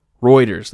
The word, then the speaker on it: Reuters: how do you say that, Reuters? Reuters, that's it, Reuters.